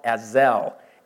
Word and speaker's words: Azel: Now, this valley is called Azel, A-Z-E-L. Sometimes it's spelled A-Z-A-L.